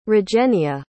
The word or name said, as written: regenia